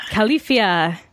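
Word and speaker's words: Califia: Did I say that right? – Khalifia, as in California.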